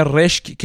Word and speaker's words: رشک: رشک که گفتیم این همون رشک هست که در فارسی امروز هم داریم صرفا اینجا اینجوری باید تلفظ بشه که با قافیه کلمه پزشک یا همون پزشک همخوان باشه